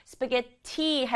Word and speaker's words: spaghetti: 스파게티 할때 소리에 T가 두개 있구나. 요런 식으로. 친구들이 전체를 외우지 않아도 단어의 생김새를 좀 뜯어보면 도움이 돼요. 발음은 스파게티, 게에 강조해 볼게요. We're having spaghetti. 그렇지, 스파게티 이렇게 발음해요.